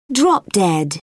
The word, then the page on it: drop dead: British English